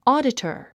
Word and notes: auditor: ɔ́ːdətər; オゥディタァ

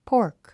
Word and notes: pork: pɔ’ː r k; ポーク

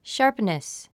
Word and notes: sharpness: ʃάː r pnəs; シャープネス

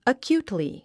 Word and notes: acutely: əkjúːtli; アキュゥトリィ

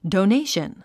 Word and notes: donation: dounéiʃən; ドォネェション

